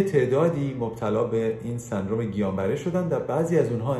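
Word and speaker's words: Guillain-Barré: سندروم گیان بره یا گیلیان بره البته اون الش اونجوری باید تلفظ بشه که گیان بره هست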